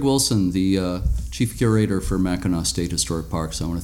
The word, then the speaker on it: Mackinac: There's sometimes some confusion about the pronunciation, even though the name of Mackinac Point is spelled MACKINAC. It's pronounced Mackinac.